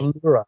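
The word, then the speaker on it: Lörrach: L-O-R-R-A-C-H. Look, help me out there. – I believe it has an umlaut over the U, correct? – Yeah, L-O. – Right, I think L-O. It's Lurach. – Lurach, okay.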